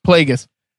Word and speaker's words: Plagueis: Plagueis.